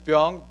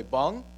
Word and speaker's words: Byung: They would say, Bung, all right, Bung. And I'd be like, no, it's not Bung, it's Byung.